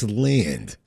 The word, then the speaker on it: Land: Okay, get his name right, huh? It's Land.